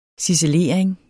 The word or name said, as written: ciselering